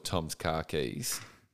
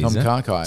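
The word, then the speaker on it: Tom Kha Kai: Just to show you that there is a soup called Tom's Car Keys. – Yeah, right. – That's pronounced Tom Car Keys. – Tom Car Kai.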